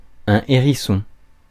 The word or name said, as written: urchin